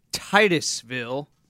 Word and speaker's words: Titusville: First, we got from Titusville. Am I saying that right? – That's right. – Yes. – That is correct.